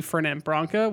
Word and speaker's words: Fernet Branca: How do you say it? – Fernet Branca. – So it's just exactly as spelt.